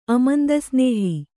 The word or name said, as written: ಅಮಂದಸ್ನೇಹ